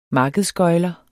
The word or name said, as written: markedsgøgler